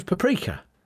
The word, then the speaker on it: paprika: Simon in Hornchurch says, Bush and Richie, can we please agree the pronunciation of paprika for your Monday stories, please? Is it paprika or paprika? – It's paprika. – It's paprika, isn't it? Paprika. – Yeah, which we've both said.